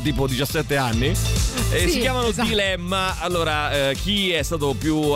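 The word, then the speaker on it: Dlemma: Loro si chiamano Dilemma, che si scrive con la D e poi Lemma, quindi praticamente Dilemma senza la I, Dilemma.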